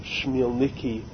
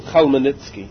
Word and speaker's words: Khmelnytsky: Different people pronounce his last name different, Shmielniki or whatever, but in Yiddish he was called Khmelnytsky.